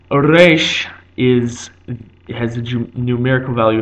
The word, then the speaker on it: resh: Resh is pronounced as it modernly is in Arabic and in Spanish. The Spanish and Arabic R is a rolled R at the front of the mouth.